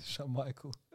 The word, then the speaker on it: Schmeichel: Schmeichel.